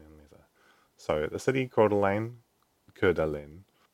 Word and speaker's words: Coeur d'Alene: Coeur d'Alene, according to local pronunciation, I would pronounce it Coeur d'Alene. I think my French pronunciation is slightly better than my American pronunciation there.